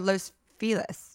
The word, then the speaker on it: Los Feliz: Is it Los Feliz or Los Feliz? – Los Feliz would be the correct pronunciation, but people say Los Feliz because I think. – Because they're dumb? – They're dumb. – Okay. – That's like the common way that people say it.